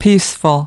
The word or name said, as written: peaceful